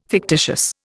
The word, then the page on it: fictitious: Indian